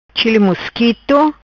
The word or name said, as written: Chili mosquito